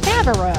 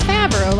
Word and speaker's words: Favaro: Favaro. It's Favaro, like John Favaro.